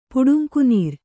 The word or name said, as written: ಪುಡುಂಕುನೀರ್